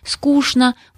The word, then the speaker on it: скучно: До сих пор в разговоре некоторых москвичей можно услышать регулярное произношение сочетания «чны» как «шны» – «скучно», «булочное», коришневый.